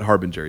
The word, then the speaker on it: harbinger: It's the right word. You just pronounced it wrong. Like, I don't know what to do with that. Harbinger, yeah.